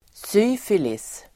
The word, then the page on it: syfilis: [s'y:filis]